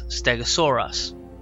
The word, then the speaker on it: Stegosaurus: So it says Steg, S-T-E-G-O-O-H, Sor, S-O-R-E, Us, Stegosaurus.